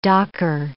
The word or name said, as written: docker